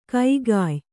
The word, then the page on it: ಕಗ್ಗಾಯಿ: kaggāyi